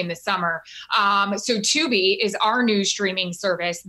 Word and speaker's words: Tubi: And being that you're on Fox, they're getting into the streaming game with Tubi. Am I pronouncing that right? Tubi. What, what you are? Yeah.